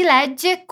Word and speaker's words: legge: Aqui você precisa se concentrar na pronúncia de legge, que tem dois Gs, e na pronúncia do S em scrive, que não tem uma vogal antes.